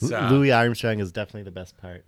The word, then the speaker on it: Louis Armstrong: Yeah, Louis Armstrong.